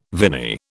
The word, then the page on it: viny: British English